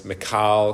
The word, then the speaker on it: Michal: Jonathan, and I've heard this pronounced different ways, Michal, because it's a girl, it's not Michael. Michal, wife of David.